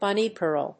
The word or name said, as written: bunny girl